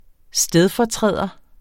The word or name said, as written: stedfortræder